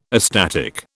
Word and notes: astatic: British English

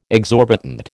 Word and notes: exorbitant: American English

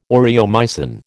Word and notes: aureomycin: American English

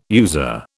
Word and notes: user: British English

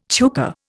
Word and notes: chukar: Indian

